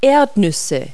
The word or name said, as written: peanuts